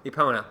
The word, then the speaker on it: Epona: I mispronounced Eponia from Legend of Zelda. – Oh, I'm sorry? – I think I still did it. – Epona? – Epona. – Yeah. – Well, what I said was Eponia, which is totally off.